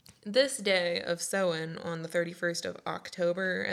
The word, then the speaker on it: Samhain: So the origins of Halloween come from the ancient Celtic festival of Samhain, mm-hmm.